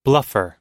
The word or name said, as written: bluffer